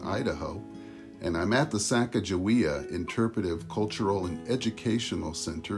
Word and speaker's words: Sacajawea: We say Sacajawea here at the Sacajawea Center, and we say Sacajawea because the Agaidika, Sacajawea's people, that is how they say it.